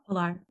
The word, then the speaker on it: Pilar: Pilar, okay.